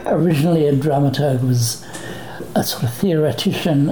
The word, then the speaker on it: dramaturg: our dramaturge. Am I pronouncing that correctly? Dramaturge? – It would normally be dramaturg.